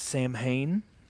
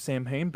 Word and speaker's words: Samhain: Some people like to pronounce it Samhain. They've got the spirit of Samhain, but it's really pronounced Samhain.